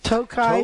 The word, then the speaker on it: Tokai: I'll make it in two syllables here because it's really easy. – Tokai.